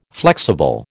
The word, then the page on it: flexible: American English